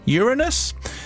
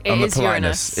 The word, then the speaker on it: Uranus: This year, if you know where to look, you'll see Jupiter, Mercury, Mars, Uranus, Saturn and Neptune. How do you pronounce that? – Uranus. – Is it Uranus? Yep. Okay. Not the other way. No.